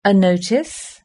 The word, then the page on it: a notice: British English